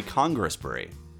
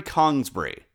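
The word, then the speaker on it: Congresbury: First, I've been saying Cadbury Congressbury, because that's how it's spelled. But apparently, it's Cadbury Kongsbury, because it's in the South, and they hate syllables down there.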